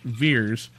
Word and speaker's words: Vers: The character, played by actress Brie Larson, is now known as Vers, pronounced Veers, on planet Hala, where she serves the Kree Empire with supernatural powers she didn't know she acquired back on Earth.